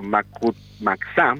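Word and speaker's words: Mactumactzá: Pues cierro la columna, nos quedamos con lo que dicen la Federación de Estudiantes Campesinos Socialistas de México, Escuela Normal Rural Mactumaxá, perdón que no puedo leer bien esta Mactumaxá, sí. Así es como se pronuncia.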